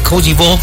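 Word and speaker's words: Côte d'Ivoire: Ya hay alineaciones por parte de Alemania ante Cody Ball. Así se pronuncia en François. Decir Costa de Marfil.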